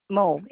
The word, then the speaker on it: Moe: Oh, Moe, M-O-E.